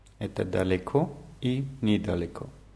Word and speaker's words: недалеко: Da wird ein N je davor gesetzt und wird als nie ausgesprochen. Недалеко. – Недалеко. – Ist, ist das ein Wort? – Das ist ein Wort, ja. Die Betonung ist bei beiden Wörtern auf dem letzten O.